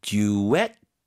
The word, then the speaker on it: duet: A beautiful duet. 뒤쪽에 힘 주세요.